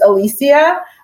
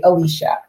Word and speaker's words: Alicia: And so I used to always say Alicia because it was just easy. And technically that is the other pronunciation. So I'm actually fine with both.